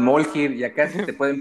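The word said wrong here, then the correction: Mjölnir: Creo que lo dije mal, Javi. ¿Cómo es? Mjolnir.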